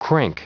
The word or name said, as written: crank